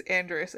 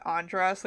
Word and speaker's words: Andrus: And, which, by the way, it is Andrus and not Andras, like they say it in the movie. Yeah. Because in the book, she calls him Andy, and that wouldn't work unless it's pronounced Andrus.